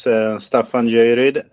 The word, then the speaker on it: Staffan Göjeryd: And so my name is Staffan Göjeryd. It's a very difficult name to pronounce in any language, even in Swedish, I must say.